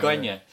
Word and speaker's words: Kanye: Kanye.